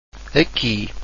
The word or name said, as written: aqui